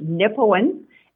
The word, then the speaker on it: Nipawin: It's Nipawin, N-I-P-A-W-I-N.